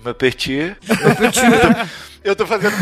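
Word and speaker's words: Maupertuis: É como, o Mapertier, agora falei certo, ele, ele era um grande defensor da, dessa religiosidade dentro da ciência e tal.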